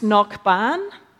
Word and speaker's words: Cnocban: There's this Irish place name that's pronounced Knockban. It's spelt C-N-O-C-B-A-N. And one option is simply to translate it phonetically into Knock, K-N-O-C-K, and then Ban, B-A-N.